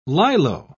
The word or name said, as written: lilo